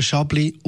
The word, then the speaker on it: Chablis: Man trinkt vermehrt wieder Weisswein und heute da reden wir über einen der bekanntesten Weisswein der Welt, nämlich den Chablis Radio 1 wein Wo kommt der eigentlich her? – Chablis geschrieben, aber Chablis ausgesprochen.